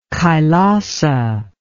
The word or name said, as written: Kailasa